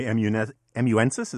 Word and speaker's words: amanuensis: Jack Butler, my amanuensis is that how you pronounce it? Yes, yes. I believe so.